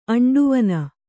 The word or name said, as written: ಅಂಡುವನ